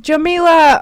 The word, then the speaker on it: Jamila: Did I pronounce it wrong? – Jamila.